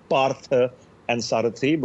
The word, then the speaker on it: Parthasarathi: It's two different words from Indian mythology. It's Partha and Sarathi, but more often than not, people, when trying to pronounce my surname, sort of end up being breathless for the wrongest reasons.